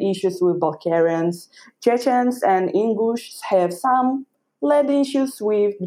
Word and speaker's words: Ingush: There's, you know, like official land issues right now between the Chechens and, how do you say, Ingush, just Ingush.